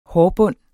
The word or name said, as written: hårbund